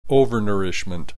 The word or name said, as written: overnourishment